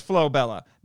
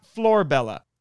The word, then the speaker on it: Flobella: And it is Flobella, not Florbella." And she writes out the Japanese for that, both in kanji and in romaji, which she says is pronounced like Flobella. The U at the end of most syllables is usually barely voiced, and as always, Japanese R is a mixture of English R and L.